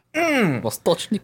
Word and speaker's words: Vostochny: You're going to have to help me with this Russian pronunciation. Vostochny Cosmodrome. – I think you nailed it. That's good. Vostochny Cosmodrome.